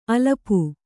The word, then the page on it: ಅಲಪು: alapu